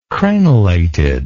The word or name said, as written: crenelated